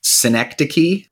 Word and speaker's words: Synecdoche: Synecdoche. – Synecdoche, New York.